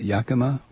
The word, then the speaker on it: Yakima: So, on this Memorial Day, I thought it was appropriate to mention Father Bix, and thanks to M.M. in Yakima, is that how you say it? Yakima, Washington, who sent this to me.